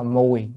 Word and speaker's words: mùi: Cái chữ này nó thường chúng ta đọc là gì, vị đó, nhưng mà thật ra nó là mùi, mùi trong năm tháng là phải mùi.